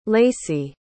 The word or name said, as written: laci